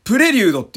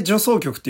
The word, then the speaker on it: 助走曲: あの、方針演技のね、ミュージカルもね、あの、回戦の助走曲って書いて、プレリュードって読むんですよ。めちゃめちゃ俺助走曲って読んでて。